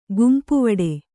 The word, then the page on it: ಗುಂಪುವಡೆ: gumpuvaḍe